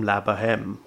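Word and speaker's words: La Bohème: La Boheme. – Yes. – Have I said that right? I'm completely uncultured. – Yeah.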